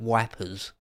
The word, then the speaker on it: Ypres: The English sometimes called it wipers.